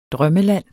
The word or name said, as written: drømmeland